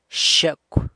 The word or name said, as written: xyecw